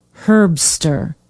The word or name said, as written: herbster